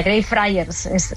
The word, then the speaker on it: Greyfriars: Así se pronuncia, Greyfriars.